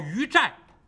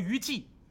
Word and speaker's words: 于祭: 叫于寨，这个字写成祭祀的祭，但是要念成寨，就是于寨，可千万不能念成于祭。